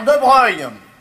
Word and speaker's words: De Broglie: Affaire de Breuil. Ça s'écrit De Broglie. C'est des noms de faucheton, ça s'écrit pas comme ça se prononce.